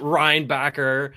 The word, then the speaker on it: Reinbacher: We can pronounce it Reinbacher. We don't have to do that German accent. Okay. Unnecessary. But I really enjoyed his game tonight.